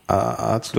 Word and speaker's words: Azubi: Ich würde Azubi kannst du gleich machen, weil du Auszubildender, weil das A, ist A ist kurz.